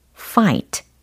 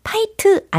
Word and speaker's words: fight: F, I, G, H, T, fight. fight. 파이트 아니고 fight. fight.